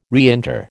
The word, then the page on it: reinter: American English